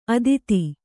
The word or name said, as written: ಅತೀತ